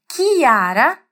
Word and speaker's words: Chiara: O que eu quero te contar, esclarecer aqui, é que em italiano esse CH jamais terá esse som de X que pronunciamos em português. É só você lembrar da prof aqui. Chiara é Chiara e não Chiara, só porque temos um CH ali.